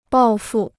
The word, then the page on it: 抱负: bào fù